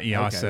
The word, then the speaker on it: EASA: EASA? – Yeah, EASA.